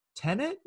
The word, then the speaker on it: Tenet: Fumi had selected Christopher Nolan's Tenet.